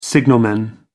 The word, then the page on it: signalman: American English